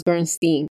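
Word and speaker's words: Berenstain: No, it's pronounced Bernstein. And I checked on the commercials for the TV show. They have the little theme song and they say Bernstein Bears.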